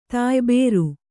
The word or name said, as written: ತಾಯ್ಬೇರು